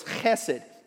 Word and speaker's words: hesed: it's pronounced hesed, and you'll hear people say hesed, but it's not an H, it's a hate, so it's hesed.